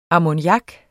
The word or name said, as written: ammoniak